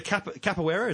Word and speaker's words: capoeira: Okay, so the capoeira, is that how you pronounce it? Capoeira? – Yep.